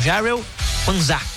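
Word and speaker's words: Jarell Quansah: Políticos britânicos estão se mobilizando para pedir à FIFA a mesma liberação do zagueiro Daryl Knash. Aliás, eu vou repetir a pronúncia aqui porque é um nome difícil, hein? É o Jarrell Panza, que recebeu esse cartão, foi expulso do confronto na Inglaterra contra o México no domingo.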